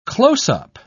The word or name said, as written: close-up